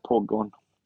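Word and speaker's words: Pogoń: det det, det, det heter ju Det bästa är att säga Poggon.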